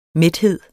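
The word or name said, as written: mæthed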